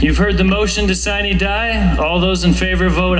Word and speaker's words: sine die: the correct pronunciation would be sine die.